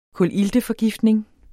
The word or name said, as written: kulilteforgiftning